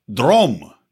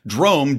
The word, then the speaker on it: Drom: And before we get into the show proper, guys, I want to tell you about a new sponsor of ours, Drom. Now, I know it's hard to pronounce. You probably are not Swedish, but Drom, D-R-O-M, is a new dating app basically designed to supersede and eviscerate all of the other sucky dating apps that exist out there.